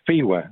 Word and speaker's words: FIWA: I mean, I pronounce our acronym FIWA. I take the second F as a silent F.